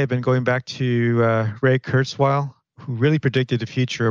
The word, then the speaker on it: Ray Kurzweil: It's Ray Kurzweil, KURZWEIL.